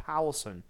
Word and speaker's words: Powlison: One is by an author by the name of David Powelson. His name is spelled his last name Powlison, David Powelson.